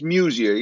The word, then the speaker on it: Muzea: Did I say your last name right? Muzea? – Yeah, it's Muzea. Yeah, it's okay.